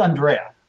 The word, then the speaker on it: Andrea: It's Andrea.